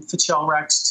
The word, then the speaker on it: Fichelrack: I went into some of my existing uh, game content and grabbed a very short, isolated snippet of an adventure that became Fichelrack's Tomb, and that's how you pronounce it, Fichelrack.